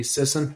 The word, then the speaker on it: Sisson: is it pronounced Sisson? – Emily Sisson. Yeah.